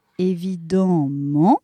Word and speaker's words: évidemment: On devrait prononcer en hein, évidemment, mais il est très difficile de prononcer deux voyelles nasales à la suite. On dit donc amant, question de facilité.